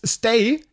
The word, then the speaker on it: Stage: Ich habe gestern eine neue Topskarte gekriegt von Werder und zwar die letzte in dieser Saison von Jens Stay. Ich nenne ihn immer Storge, ne, aber warum Stage geschrieben, Stay ausgesprochen wird, werde ich auch nie verstehen, aber der heißt ja nochmal so.